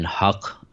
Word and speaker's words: Haq: And then Haq, which is actually how you pronounce, like, my last name, is truth.